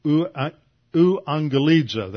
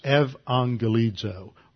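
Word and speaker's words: euangelizo (εὐαγγελίζω): That's how we usually pronounce it, although the U, the upsilon, is historically in Greek was pronounced as a V, which is why we get it pronounces evangelizo, or evangelism.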